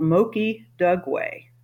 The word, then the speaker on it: Moki Dugway: It's called Moki Dugway, and that's spelled M-O-K-I, Moki Dugway, D-U-G-W-A-Y.